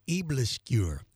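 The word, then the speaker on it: aebleskiver: Her name is spelled P-I-M, last name Over, O-V-E-R, G-A-A-R-D. Eblisqueur is how it's pronounced. It's spelled A-E-B-L-E-S-K-I-V-E-R, a new take on traditional Danish pancakes.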